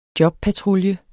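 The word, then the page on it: jobpatrulje: Danish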